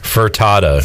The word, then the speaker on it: Furtado: There's no R. – Furtado.